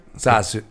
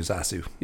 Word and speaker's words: Zasu: Did I get the name right? – Zasu. It's Z-A-S-U, Zasu. – Yeah, Zasu. Yeah, yep.